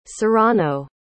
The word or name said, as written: Sorano